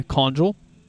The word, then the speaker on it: Conjul: By the way, Conjul. I didn't realize it was with a soft G. Soft G.